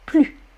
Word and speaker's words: plus: Ok Alors, comprenez bien, une comparaison entre des adjectifs, des adverbes, qui commencent par une consonne, on ne prononce pas le S. Plus. Ma mère est plus petite que mon père.